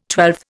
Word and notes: twelfth: Indian

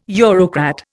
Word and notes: Eurocrat: Indian

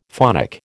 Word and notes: phonic: American English

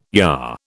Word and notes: yah: British English

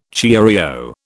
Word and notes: cheerio: British English

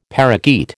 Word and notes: parakeet: American English

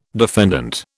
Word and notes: defendant: British English

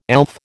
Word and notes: elf: American English

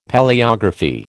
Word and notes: Palaeography: American English

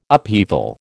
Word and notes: upheaval: American English

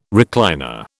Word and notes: recliner: British English

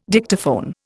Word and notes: Dictaphone: Indian

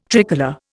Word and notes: tricolour: Indian

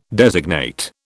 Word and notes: designate: British English

